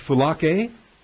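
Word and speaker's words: Phulake (φυλακή): The noun is phulake. Phulake, accent on the A.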